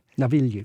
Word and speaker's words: Navigli: And, and how do you say this district, the canal district? Navigli.